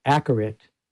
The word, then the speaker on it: Acourate: And you can see that corrected by a program called Accurate. I know it's pronounced weird, but it's spelled A-C-O-U-R-A-T-E, and a companion program called Accurate Convolver.